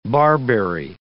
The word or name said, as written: barberry